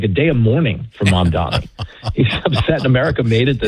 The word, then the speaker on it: Mamdani: I always say it wrong. Is it Mamdani? Mamdami? Yes. Mamdani.